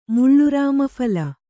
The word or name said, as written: ಮುಳ್ಳುರಾಮಫಲ